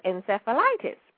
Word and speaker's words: encephalitis: It's E-Q-U-I-N-E. – Oh, yes, equine. Sure. – Uh-huh, and I think it's encephalitis, E-N-C-E-P-H-A-L-I-T-I-S, yes, encephalitis. – Spell it again. – E-N-C-E-P-H-A-L-I-T-I-S.